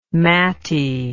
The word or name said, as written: matty